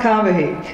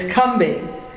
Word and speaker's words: Combahee: It is not Combahee, it's Cumbee. – Wow. – Yeah, C-U-M-B-E-E.